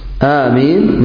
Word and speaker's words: آمين: آمين مثل ياسين.